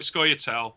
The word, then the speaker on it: Scoia'tael: Have I pronounced Scoia'tael. That right? Scoia'tael.